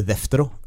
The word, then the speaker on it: deutero: Daut, Deutero, altså deutero kanonisk et gresk begrep, og deutero betyr jo andre. Eller eh, deftero, som det da mm. uttales.